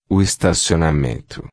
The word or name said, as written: estacionamento